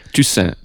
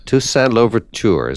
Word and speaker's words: Toussaint Louverture: Let's talk for a few minutes, about, help me with the pronunciation here, Toussaint? – Toussaint. – Toussaint Louverture, is that the way one says it? – Indeed.